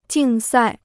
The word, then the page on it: 竞赛: jìng sài